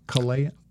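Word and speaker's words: Kalayaan: How do you say that? – Kalayan.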